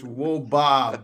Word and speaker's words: wOBA: You're not even saying it right. And that's very disappointing. It's really disappointing for a man in academia to not even be able to say WOBA. But it's WOBA, BA, BA.